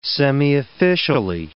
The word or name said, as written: semiofficially